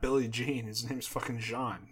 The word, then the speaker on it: Jean: Yeah, he's from the DR, but his If you see anybody from the DR and their name is Jean, don't fucking say it like that. It's Jean, like Wyclef Jean. You wouldn't say Wyclef Jean.